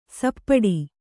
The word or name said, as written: ಸಪಾಟಿ